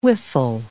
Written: whiffle

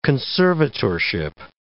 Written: conservatorship

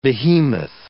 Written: behemoth

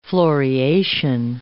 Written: floriation

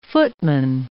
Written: footman

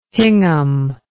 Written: hingham

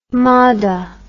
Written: hammada

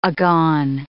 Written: agone

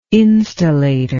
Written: instillator

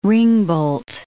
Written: ringbolt

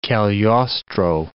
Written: Cagliostro

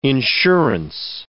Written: insurance

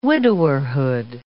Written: widowerhood

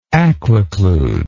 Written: aquiclude

